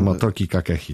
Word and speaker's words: Motoki Kakehi: Ale jeśli chodzi o nowości w składzie, jeśli chodzi o świeżą krew, to klub związał się z japońskim zawodnikiem Motoki Kakehi. Nie wiem, czy dobrze wymawiam. – Motoki Kakehi, tak.